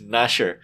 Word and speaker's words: Gnasher: And the secret was that Gnasher's name is pronounced Nasher.